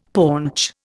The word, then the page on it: paunch: Indian